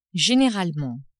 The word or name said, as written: generalement